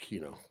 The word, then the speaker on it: Chino: But yeah, because Chino is it Chino or Kino? I'm saying Kino. – Yeah, it's in the American dub. It's pronounced Kino.